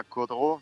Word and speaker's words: Caudrot: Estamos acá en Francia, en un pueblito que se llama Caudreau, se escribe Caudrot, a 65 kilómetros al sur de Burdeos y es una zona, bueno, como de, renombre a nivel, digamos, global.